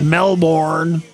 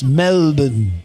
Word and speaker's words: Melbourne: You have to say it right. They don't like it. It's not Melbourne. – Right. – It's Melbourne. Melbourne.